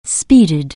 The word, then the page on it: speed: anglais